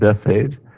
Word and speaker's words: Bethphage: And so it says in chapter Matthew 21, verse 1, it says, Now when they drew near Jerusalem and came to Bethphage, that's how you say it, Bethphage,